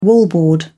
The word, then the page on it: wallboard: British English